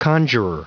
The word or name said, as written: conjurer